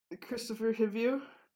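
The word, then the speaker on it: Kristofer Hivju: Christopher Hivju, thank you, was looking at her, she said the looks were pure sex.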